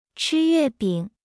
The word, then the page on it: 吃月饼: 0.8x